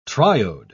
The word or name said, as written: triode